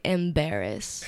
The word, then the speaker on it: Embarees: My music name is actually Embarrass. – Embarrass. – It's spelled a little differently. E-M-B-A-R-E-E-S.